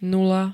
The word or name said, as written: nula